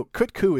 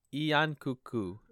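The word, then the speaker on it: Yian Kut-Ku: Also, kut ku is really fun to say. – Kut ku. Yon-Kut Ku is how you say it in Japanese. Same thing.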